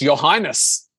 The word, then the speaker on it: Urhiness: Yeah, it's spelled U-R-H-I-N-E-S-S and it's pronounced Your Highness.